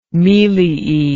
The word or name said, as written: meliae